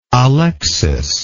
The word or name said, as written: Alexis